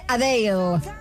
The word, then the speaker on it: Adele: En un encuentro con fans, la cantante británica ha comentado que llevamos pronunciando mal su nombre desde que la hemos conocido. Ajá. Que no se dice Adele, que se dice Adele.